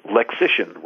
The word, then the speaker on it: Lexician: Is Lexician, rhymes with magician.